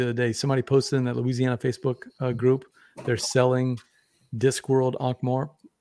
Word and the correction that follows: Ankh-Morpork: Ankh-Morp? Is that how you say it? – Ankh-Morpork. – Ankh-Morpork.